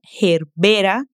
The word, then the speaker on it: gerbera: Essa também tem quase o mesmo nome em português, mas a gente muda a sílaba mais forte. Em espanhol é herbera e em português é gerbera.